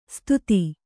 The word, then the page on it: ಸುದತಿ: Kannada